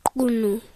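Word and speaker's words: Qunu: He was born in Mvezo, but he grew up in Kunu.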